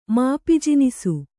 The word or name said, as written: ಮಾಪಿಜಿನಿಸು